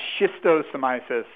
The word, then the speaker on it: Schistosomiasis: Schistosomiasis.